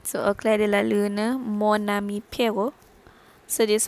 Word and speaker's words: lune: So, dia sebut Luna. Dia sebut E tu. But the correct pronunciation is lune.